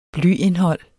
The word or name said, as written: blyindhold